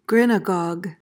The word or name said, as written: grinagog